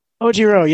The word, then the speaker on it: Ojiro: Ojiro. Ohiro? He's got Ojiro. – The tail. – Ojiro, yes, the tail.